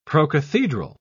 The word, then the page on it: procathedral: English; British English